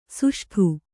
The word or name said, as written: ಷಷ್ಠ